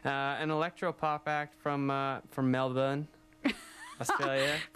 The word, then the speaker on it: Melbourne: They're from Melbourne, Australia. Or how would you say that? – Melbourne. – There you go. – Not Melbourne.